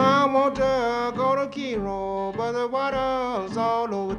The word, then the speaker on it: Cairo: This first tune looks like it should be pronounced Cairo Blues after the city in Egypt, but this is not the same town. This is on the Mississippi River in southern Illinois, and there they pronounce it Cairo.